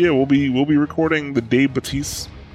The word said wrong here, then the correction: Baptiste: goes live, or Baptiste goes live. Sorry, I said his name wrong. – The P is not silent.